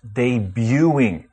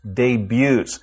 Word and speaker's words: debut: And by the same reasoning, the progressive form should be pronounced debuting, and the plural form is debuts. You pronounce all of them without the T sound.